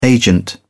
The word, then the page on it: agent: British English